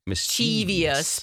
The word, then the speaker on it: mischievous: Because people pronounce it mischievous, but it's mischievous.